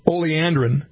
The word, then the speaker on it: oleandrin: Some of you want to know more about this oleandrin.